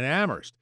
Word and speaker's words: Amherst: Helena in Amherst says, where only the H is silent.